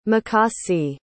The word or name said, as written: mikasi